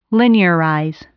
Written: linearize

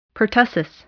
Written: pertussis

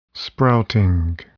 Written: sprouting

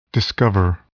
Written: discover

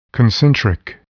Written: concentric

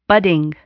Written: budding

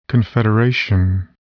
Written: confederation

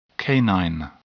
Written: canine

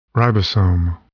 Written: ribosome

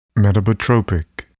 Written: metabotropic

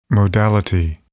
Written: modality

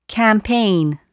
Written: campaign

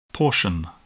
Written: portion

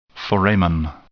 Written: foramen

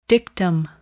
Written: dictum

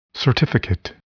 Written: certificate

